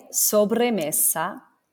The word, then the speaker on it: sobremesa: S-O-B. R-E-M-E-S-A. So again, now I'm going to say it, sobremesa, sobremesa.